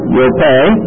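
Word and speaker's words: Yope (Ἰόππῃ): IOPP long E, Yope, and that is modern day Joppa.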